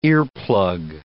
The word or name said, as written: earplug